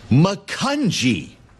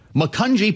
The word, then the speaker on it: Macungie: Macungie. It's a soft G. Macungie, Pennsylvania.